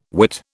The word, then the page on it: Whit: British English